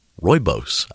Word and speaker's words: Rooibos: Rooibos, I believe, is what it's called.